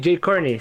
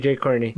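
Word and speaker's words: Jai Courtney: Bueno, es que se escribe Jai su nombre, no sé si. Jacob. – La verdad es que. Ah, sí, Jay Corney. Sí, Jay Corney.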